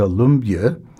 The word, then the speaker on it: Lumbye: Here is a polka mazurka by the Danish composer Hans Christian, we'll say Lumbye, although I think the authentic pronunciation is closer to Lumbye.